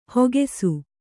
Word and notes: ಹಾಕಿಸು: hākisu

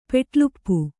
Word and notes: ಪೆಟಲುಪ್ಪು: peṭaluppu

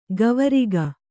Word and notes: ಕವರಗಿ: kavaragi

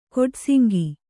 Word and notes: ಕೋಡಸಿಂಗೆ: kōḍasiŋge

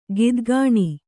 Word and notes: ಗಿದ್ಗಾಣಿ: gidgāṇi

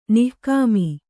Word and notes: ನಿಕಾಮ: nikāma

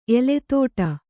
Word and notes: ಎಲೆತೋಟ: Kannada

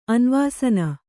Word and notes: ಅನ್ವಾಸನ: anvāsana

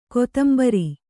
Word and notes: ಕೊತ್ತುಂಬುರಿ: kottumburi

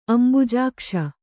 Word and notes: ಅಂಬುಜಾಕ್ಷಿ: Kannada

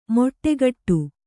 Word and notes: ಮೊಟ್ಟೆಗಟ್ಟು: kannada

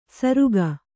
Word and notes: ಸರುಗ: kannada

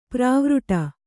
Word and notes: ಪರಿವೃಢ: Kannada

